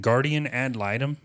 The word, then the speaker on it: guardian ad litem: Is it guardian ad litem? – Yes, that was correct.